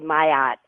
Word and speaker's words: Maat: On the wild card line from New York City, Amayat. I hope I'm not mispronouncing that too much? – Well, you were close. It's actually Maat, the Kemetic Egyptian goddess of truth, justice, right, and balance.